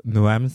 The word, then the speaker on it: Noam: En gros, mon qui, ça va être euh, un humoriste slash poète slash artiste slash euh, danseur de voguing qui s'appelle Noam Sanso, que vous connaissez peut-être. Noam, ça s'écrit N-O-A-M et Sanso, S-I-N-S-E-A-U, pour les gens qui nous écoutent.